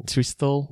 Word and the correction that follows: Thestral: It's just a pronunciation. – Yeah. – So it's a Thestral. – Thestral.